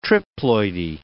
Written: triploidy

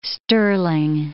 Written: sterling

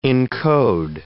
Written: encode

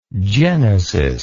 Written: genesitic

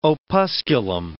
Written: opusculum

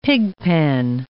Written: pigpen

